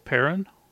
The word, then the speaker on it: Perrin: I played Perrin, who is Aaron's son. – What's his name? – Perrin. It is Perrin. Yeah, P E R R I N.